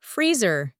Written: freezer

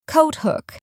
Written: coat hook

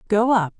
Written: go up

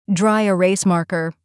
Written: dry erase marker

